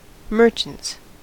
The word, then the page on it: merchants: American English